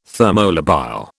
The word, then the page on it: thermolabile: British English